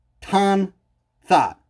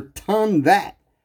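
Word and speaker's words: Ton-That: Tan thought or ton that. It's spelled T O N hyphen T H A T. So ton that. Ton that or ton thought.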